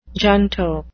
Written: junto

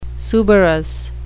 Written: suberous

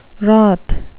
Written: wroth

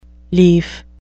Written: lief

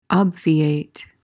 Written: obviate

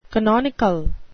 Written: canonical

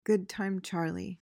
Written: good-time charlie

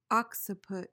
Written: occiput

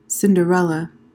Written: cinderella

